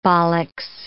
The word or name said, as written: bollix